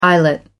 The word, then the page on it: eyelet: American English